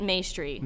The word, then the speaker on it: Maestri: Well, we say it Maestri. – Maestri. – Like pastry.